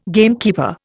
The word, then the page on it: gamekeeper: Indian